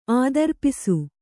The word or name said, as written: ಆದರ್ಪಿಸು